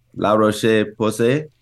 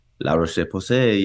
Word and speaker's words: La Roche-Posay: He pronounced it pretty well. – La Roche-Posay. – There you go. – See, was that French? – La Roche-Posay. Yes, French.